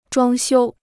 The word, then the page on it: 装修: zhuāng xiū